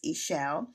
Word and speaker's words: Eshel: It's spelled with an I, but it's Eshel Dream Space.